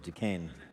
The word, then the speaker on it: Duquesne: Now, I know French people would insist that it be pronounced differently than that, but we won the war, so it's Fort Duquesne.